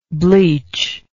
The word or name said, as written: bleachability